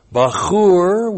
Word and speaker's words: bachur: And this is the plural of the noun bachur with the second person plural masculine suffix plus the kal imperfect of the verb ra'ah plus the plural of the noun kitzayon. Bachur. B-A-C-H long U-R.